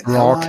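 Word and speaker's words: ROG: Und ich bin da ewig lang drum rumgeschlichen, Steam Deck oder Republic of Gamers, spricht man wahrscheinlich Rock oder Rock, irgendwie. – Rock, Ally, ja.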